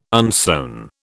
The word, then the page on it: unsewn: British English